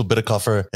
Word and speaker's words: Bitecofer: Okay, so at Rachel Bitterkopfer, it's spelled B-I-T-E-C-O-F-E-R. And then Strike Pack is Strike, S-T-R-I-K-E, Pack.